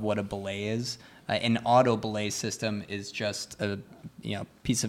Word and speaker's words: auto belay: What's a auto belay? – Yeah.